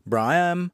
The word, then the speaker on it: Braham: I've seen Braham, Brahim, Braham, and I say Braham. So it's got a little hum there at the end.